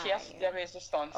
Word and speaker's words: pièce de résistance: So now, the pièce de résistance. I think I said that right. Ooh. I've been practicing. – Yes. – Because you know how pièce de résistance.